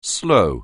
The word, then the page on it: slow: anglais